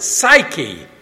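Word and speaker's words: psyche (ψυχή): The first one is psuche. We would pronounce it psyche.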